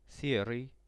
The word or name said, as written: seryj